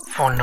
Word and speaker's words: Phaune: Phone radio. It's spelled P-H-A-U-N-E, but it's pronounced phone.